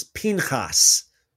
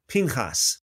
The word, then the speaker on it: Pinchas: Again, Phinehas in the original Hebrew way, as far as I know, more accurate way to pronounce it is Pinchas. Pinchas.